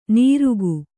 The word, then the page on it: ನಿರುಕು: niruku